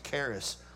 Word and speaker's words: charis: Again, you have found, I think it's the Greek word, you pronounce it charis, grace.